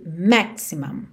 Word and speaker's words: maximum: O cuidado que você tem que ter é só para esse u não ficar muito u, não é mum, mum é mam, mam, ok? – Então Minimum Waste. E essa sílaba que tem o M e o M é a última sílaba. E as sílabas tônicas são as primeiras nessas duas palavras. Então você tem minimum e maximum, ok? Minimum, maximum.